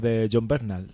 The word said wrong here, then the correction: Bernthal: ¿Cómo se pronuncia? John Bernthal. Bernthal, ¿no? Sí.